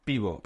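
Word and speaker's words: piwo: Pivo.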